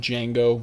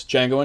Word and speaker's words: Django: But anyways, if we're going chronologically, after Bastards comes Django Unchained. – Django! The Django! – The D is silent, hillbilly.